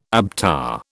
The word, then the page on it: ABTA: British English